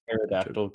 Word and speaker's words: pterodactyl: Why can't you hear pterodactyl go to the bathroom? – Why? – Because the P is silent.